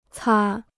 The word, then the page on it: 擦: cā